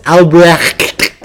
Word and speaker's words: Albrecht: Albert like, Durer. Albert Durer. It's pronounced Albrecht.